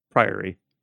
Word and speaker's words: Priory: Priory. I don't know why I pronounce it like that. – It's priority, but without the IT.